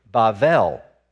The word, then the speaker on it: Bavel: But what's interesting is in the Hebrew, it's Bavel. So if you want to pronounce this, if you want to say this accurately, the way they said it all those years ago, it's Bavel, right?